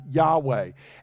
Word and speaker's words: Yahweh: Many times in your English Bibles, if you look and you see Lord, not capital L, lowercase O-R-D, but all caps, L-O-R-D, that means they are translating the covenant name of God, this Y-H-W-H, which we sometimes again pronounce as Yahweh.